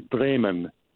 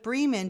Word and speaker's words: Bremen: I just wanted to make a gentle comment about the correct pronunciation of the town Bremen, B-R-E-M-E-N. The fully correct German pronunciation would be Bremen, not Bremen.